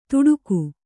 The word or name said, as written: ತುಡುಕು